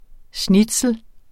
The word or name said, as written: schnitzel